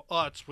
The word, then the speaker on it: Utz: and now they're a subsidiary of Utz, which has to be the stupidest name for a company because it rhymes with putts.